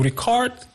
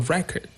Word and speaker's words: record: Now, for the noun, you say a record. To record, as a verb, the stress is on the second syllable. And as a noun, we say a record.